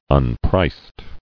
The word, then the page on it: Unpriced: un·priced